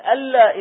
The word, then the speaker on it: أن لا: فأنت الآن تقول ألا إله إلا الله، أشهد ألا إله إلا الله، وتكتب أن لا إله، فالكتابة صارت خلاف النطق.